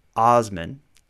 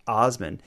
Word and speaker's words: Assman: So Dick Assman is his name, but it's spelled Assman, pronounced Osman.